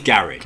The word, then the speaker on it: garage: Actually, there's three ways to pronounce garage. One is garage. – Garage, yeah.